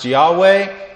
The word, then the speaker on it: Yahweh: That you know that the name for God in the Old Testament is, it's pronounced Yahweh or Jehovah.